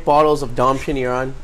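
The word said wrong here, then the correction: Dom Pérignon: Is that how you say it, Seuss? Dom Pignon. – Dom Perignon. – What? – The champagne Dom Perignon.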